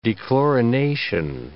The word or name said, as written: dechlorination